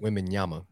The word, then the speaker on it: Wembanyama: You're not getting Wembanyama. Did I pronounce that right? – Yeah, good enough. – Did I pronounce that right? – Wembanyama.